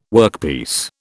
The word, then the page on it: workpiece: British English